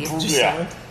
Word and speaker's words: Imbruglia: Do you not say the G? Imbruglia. – No, you don't say the G. – Imbruglia. – Why is the G silent? – I'm not Australian or Natalie Imbruglia, so I'm not going to answer that.